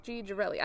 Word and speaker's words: Girelli: That sounds right. Girelli. I looked this up three times. – Girelli. – I looked it up several times, and I had it, like, phonetically written out.